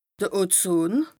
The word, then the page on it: de Ozon: Bas Rhin